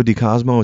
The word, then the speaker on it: Di Cosmo: Francesco Di Cosmo.